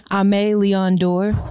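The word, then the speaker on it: Aimé Leon Dore: Say that one more time because I've never heard nobody pronounce that right. – Correctly, right? – Amelie Ondour.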